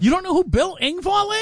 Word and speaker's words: Engvall: Man, Brandy says, Bill Engvall. Did I say that right? – Engvall, yeah.